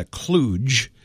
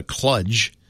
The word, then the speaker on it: kludge: It's spelled K-L-U-D-G. Now, I call that a kludge. I've heard people call it a kludge. I think it's a kludge.